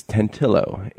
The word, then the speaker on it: Tantillo: It's Tantillo. It's an Italian name, and I was using the Spanish pronunciation.